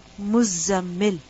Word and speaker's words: Muzzammil: And this word is actually mutazammil. Mutafa'il. Mutazammil. And the ta has been assimilated with the zai. So the word mutazammil changes into muzzammil. This is why it's necessary to pronounce the shadda.